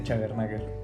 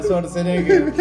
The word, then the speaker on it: Schwarzenegger: Oh, sí, o sea, porque, porque realmente el pronunciar el Schwarzenegger de repente sí era mi mamá, complicado. Mi mamá le dice Chagernager.